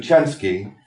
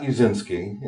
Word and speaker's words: Uscinski: And I was speaking to her the other day, and the first thing she said to me is that I pronounced my name wrong. She told me it's actually Uscinski, not Uscinski, and that's an American butchering of the name.